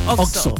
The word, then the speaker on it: Oxxo: Tú conoces muy bien qué es un Oxxo.